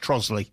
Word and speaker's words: Trotterscliff: Now, I know it is spelt Trotterscliff, but it's pronounced Trosley. – Well, it's all wrong. – Yeah. – See? – I said Trotterscliff.